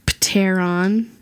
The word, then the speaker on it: Pteron: Which has a silent P at the beginning of it. – Pteron.